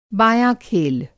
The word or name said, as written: बाँया-खेल